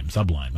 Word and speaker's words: Sublime: emphasis on the sub? – On the sub, yeah. Like, way back in the day, they came into the studio, and they kept talking about Sublime.